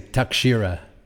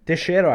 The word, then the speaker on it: Teixeira: I always say Takshira. – Teixeira, I think it is. It's Teixeira. Like, I cannot do the Brazilian accent, Teixeira, but it's Teixeira. Yeah. I think the X is not an X.